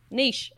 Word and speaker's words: niche: Niche. – Niche. – It's like you're baking a quiche.